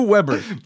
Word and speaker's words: Weber: Blue, did you say blue beber? Blue. Blue Weber. Blue, Blue Weber. Well, I think it's pronounced Weber because it's in German, no? And W is pronounced as B in German.